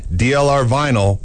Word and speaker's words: DLR Vinyl: Remember, for fencing, DLR vinyl rhymes with final.